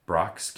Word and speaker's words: Brach's: So I don't mind getting the, I'm going to probably say this wrong, the Brax jelly beans. Brox? – What's that? – B-R-A-C-H-S. Brox candy.